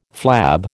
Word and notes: flab: American English

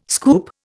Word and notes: scoop: Indian

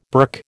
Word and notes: burk: American English

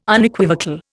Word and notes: Unequivocal: Indian

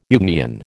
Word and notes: Jungian: American English

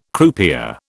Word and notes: croupier: British English

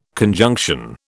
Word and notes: conjunction: British English

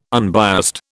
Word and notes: unbiased: British English

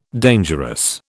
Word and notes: dangerous: British English

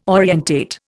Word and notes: orientate: Indian

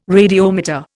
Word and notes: radiometer: Indian

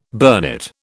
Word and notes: burnet: British English

